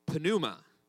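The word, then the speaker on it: pneuma (πνεῦμα): Now, the word in Greek for spirit is pneuma, right? They pronounce the P, all right?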